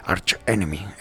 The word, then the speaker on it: Arch Enemy: De los Arch Enemy. Estuvo en esta banda de Agonist, y luego, pues bueno, cuando se fue la primera cantante, pues entró ya Alisa Whiteglass, entró en Arch Enemy. Arch Enemy, ya está, bien pronunciado.